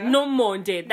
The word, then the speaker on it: Nomonde: Oh, Mamande. Sorry, guys. Okay, I butcher her name when I don't agree with her. That's my love language for Nomonde. That's how you say it. Nomonde.